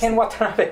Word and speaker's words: Ken Watanabe: It's Ken Watanabe one.